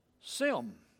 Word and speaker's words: Shem: Now, Shem, they didn't pronounce the H in ancient times, so his name was pronounced Sim.